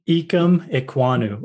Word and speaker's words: Ikem Ekwonu: Ikem Ekwonu or Ike.